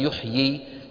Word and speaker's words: يحيي: فنحن الآن ماذا ننطق أيها الإخوة؟ نقول يحيي ويميت، يي كم ياء عم ننطق؟ ياءين، الياء الأولى مكسورة يي والثانية ساكنة يي، انتبهنا؟